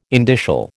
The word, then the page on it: indicial: American English